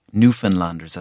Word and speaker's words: Newfoundlanders: And many people I met would identify first as Newfoundlanders, as they say it. It's pronounced like Newfoundland almost.